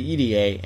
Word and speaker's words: Irie: I rarely ever hear anyone use that pronunciation, but apparently from Shigehiro Irie's mouth himself, he told Cole Radrick it is pronounced E-D-A, like the letters E-D-A.